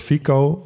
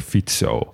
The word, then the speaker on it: Fico: Ja, in de Nederlandse media zal die waarschijnlijk ook Robert Fico worden genoemd. Want zo zouden wij het gewoon uitspreken. Eh, maar een C in het Slovaak spreek je uit als TS. Dus vandaar Robert Fico.